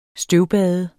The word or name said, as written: støvbade